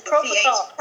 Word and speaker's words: propofol: There's no T anywhere. Propofol. – That made me feel like Sylvester. – Propofol. – Propofol, yes. People. With an L. So, the correct way to say it is propofol.